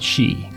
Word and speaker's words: zi: The Sumerians had a word for the soul, she, that's spelled Z-I.